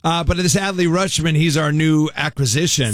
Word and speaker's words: Rutschman: I believe it's pronounced Rutschman, but it's spelled. So his first name's Adley. – Okay. – R-U-T-S-C-H-M-A-N. Rutschman. But they say it like Rutschman, but it's probably Rutschman.